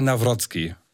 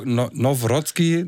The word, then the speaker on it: Nawrocki: Now Nawrocki, so in der Art. – Genau, also Rocky würde man ja auf Polnisch Rocki aussprechen und naja, Now Rocky Nawrocki, ja okay.